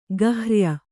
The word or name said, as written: ಗಹ್ರ್ಯ